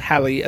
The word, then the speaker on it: Halle: I think it's pronounced Halle as well. It's spelt differently, Halle. Bailey.